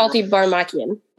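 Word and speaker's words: Altybarmakyan: I probably said it wrong, but I think that's how it's pronounced. – Yeah, it's Alti Barmakian.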